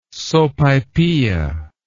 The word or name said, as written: sopaipilla